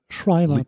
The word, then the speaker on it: Schreiber: Schreiber, Sch Sch Schreiber? – Schreiber, tatsächlich.